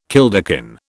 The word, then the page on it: kilderkin: British English